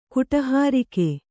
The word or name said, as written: ಕುಟಹಾರಿಕೆ